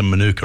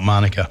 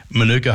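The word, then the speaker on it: mānuka: And I bought a jar of what you're supposed to pronounce a Manuka, aren't you? Not Manuka. Manuka honey.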